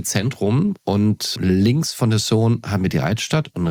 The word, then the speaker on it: Saône: Ist es Saône oder nein, es ist die Saône.